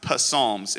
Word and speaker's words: Psalms: It's pronounced Psalms, if you're wondering. Psalms. Why is that P there? It's silent.